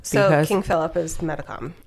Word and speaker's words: Metacom: It's Metacom.